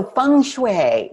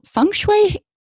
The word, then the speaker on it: feng shui: Some people have a hard time pronouncing it because they look at the letters and say, wait a minute, isn't there an E in there? Yes, but it's pronounced feng and shui.